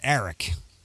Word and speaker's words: Aric: Well, my legal name is spelled A-R-I-C. And yeah, you told me that. – It's pronounced Eric.